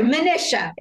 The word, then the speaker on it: Manisha: Not to worry. Manisha. – Manisha. Oh my God. Rhymes with Patricia.